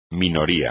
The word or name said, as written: minoría